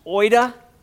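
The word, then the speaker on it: oida (οἶδα): The word here is a Greek word that is often pronounced oida. And oida actually is the perfect tense of the word to see.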